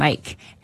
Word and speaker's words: Myke: And it's spelled M-Y-K-E. – M-Y-K-E. Yep. Mike.